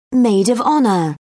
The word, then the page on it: maid of honour: British English